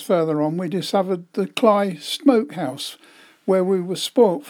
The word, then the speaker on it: Cley: We stopped at Cley next to Sea, that's spelt C-L-E-Y, but it's pronounced by the Norfolk Dumplings as Cley, so I call it Cley next to Sea, which is the scene of recent US helicopter crash on the salt marshes.